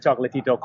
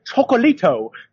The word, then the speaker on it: Chocolatito: with Chocolatito, calling him Chocolito.